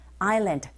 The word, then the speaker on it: island: Like the reed island. S가 보이지만 묵음인 거 아시죠?